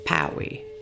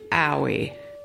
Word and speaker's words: pawi: The ejective P sound in Tsklallam is pronounced just like the English letter P, but with a stronger pop. Here is the word twice more. Pawi, pawi.